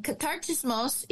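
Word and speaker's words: Katartismos (καταρτισμός): And Katartismos is the Greek word that means to equip.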